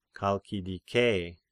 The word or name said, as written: Chalkidike